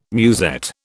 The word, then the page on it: musette: British English